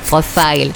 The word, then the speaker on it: Profil: Non, Profile. Ça s'écrit Profil, mais on dit Profile.